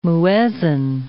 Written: muezzin

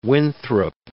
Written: Winthrop

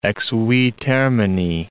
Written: ex vi termini

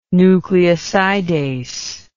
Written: nucleosidase

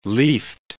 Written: leafed

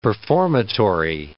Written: performatory

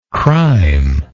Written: crimelessness

